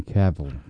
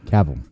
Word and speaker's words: Cavill: Henry Cavill. Cavill.